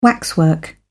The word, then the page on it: waxwork: British English